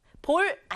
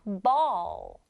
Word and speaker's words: ball: b 소리 나는 b 있고요. a, l, l 같이 만나서 all 하고 소리 나요. all. all. 붙여서 ball. 볼 아니고요. ball. ball 하면은 우리 갖고 노는 공 말한다.